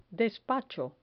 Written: despacho

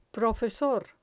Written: profesor

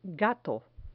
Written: gato

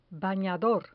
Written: banador

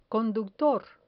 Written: conductor

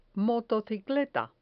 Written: motocicleta

